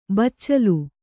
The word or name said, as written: ಬಚ್ಚಲ್